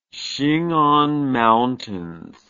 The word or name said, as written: Khingan Mountains